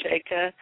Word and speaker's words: Sheikha: The word is Sheikh. It's pronounced Sheikh or Sheikha, and that sounds fine, what you said.